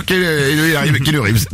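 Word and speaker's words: Keanu Reeves: Bah ouais, il y avait Kenu Bliblif, Kenu Reeves.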